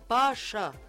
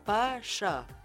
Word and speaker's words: باشا: باشا يا أخوانا كلمة تركية تنطق مفخمة مش مرققة، باشا بي تقيلة، يعني تقيلة شوية، بي بي باشا معناها ولاية أو وزارة.